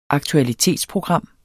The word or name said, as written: aktualitetsprogram